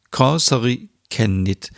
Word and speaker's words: Kalsarikännit: Und zwar geht es um, haltet euch fest, hoffentlich kann ich es richtig aussprechen, Korsari Kenit. Ich habe extra eine Finnin gefragt, dass sie mir eine Sprachnachricht schickt und mir sagt, wie man es genau ausspricht. Korsari Kennit.